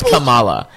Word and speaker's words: Kamala: You were, it's pronounced Kamala.